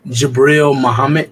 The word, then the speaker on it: Jabril Muhammad: Jabril Muhammad, J-A-B-R-I-L Muhammad.